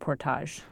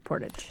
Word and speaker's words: portage: And I was fascinated by the fact that here you were in the wilderness, and your job was, is it pronounced portage or portage? – Well, in Canada, we pronounce it portage, and here in the U.S., they say portage.